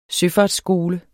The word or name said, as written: søfartsskole